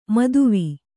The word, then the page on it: ಮೋದವಿ: Kannada